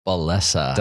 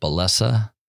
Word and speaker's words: Bellessa: Bellessa. – Did I say it right? – You did. Good job. Thank you. I hear like Bellessa, Bellessa, Bellessa, but Bellessa.